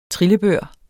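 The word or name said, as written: TRILLEBØR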